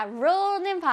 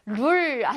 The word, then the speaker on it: rule: 룰 아니고 rule. 혓바닥을 뒤에서부터 말아서 rule. 시작. rule. 오케이. 근데 L이 있으니까 끝에는, 자, 혓바닥이 앞쪽으로 나와야 됩니다.